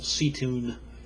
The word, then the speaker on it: Seatoun: On Friday the 29th, Carolyn Udstrom took me out sightseeing to the part of Wellington called Seatoun, as we call it Seatown, but that's the way it's spelled and pronounced, and up onto Mount Victoria and to the New Zealand National Museum.